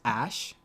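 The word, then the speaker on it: Æ: So the new name is XAE, which is pronounced Ash, Elon explained on Joe Rogan podcast.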